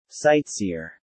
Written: sightseer

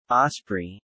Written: Osprey

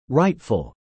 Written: rightful

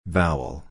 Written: vowel

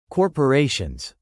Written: corporations

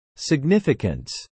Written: significance

